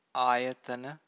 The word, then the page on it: ayatana: Pali